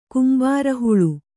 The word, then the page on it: ಕುಂಬಾರಹುಳು: kumbāra huḷu